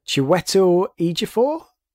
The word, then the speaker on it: Chiwetel Ejiofor: The next guy, I'm going to get his name wrong because I've never known how to pronounce it, but Chiwetel Ejiofor. I don't know if you know him. He's been in quite a lot of stuff. So what was that? – I said you nailed it.